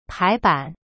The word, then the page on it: 排版: pai ban